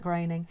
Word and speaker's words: Groening: Did you know that the Simpsons creator, Matt, I think it's Groening. I never know how to pronounce it. – It's sort of spelled Groening, but I think Matt Groening.